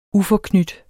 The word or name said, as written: uforknyt